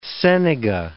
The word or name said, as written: senega